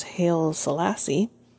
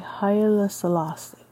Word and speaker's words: Haile Selassie: For most people, Hitler was not quite a menace as yet, not nearly so much as Haile Selassie.